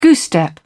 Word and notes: goose step: British English

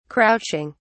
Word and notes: crouching: British English

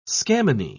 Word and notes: scammony: British English

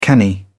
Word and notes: canny: British English

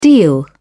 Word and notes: deal: British English

